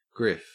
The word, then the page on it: griff: Australian English